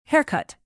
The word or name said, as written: haircut